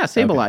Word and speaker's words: Sableye: Yeah, Sableye.